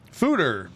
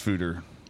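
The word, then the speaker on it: foeder: Fooder, pronounced Fooder. – So it is pronounced Fooder.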